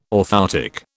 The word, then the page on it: orthoptic: British English